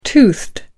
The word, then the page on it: toothed: American English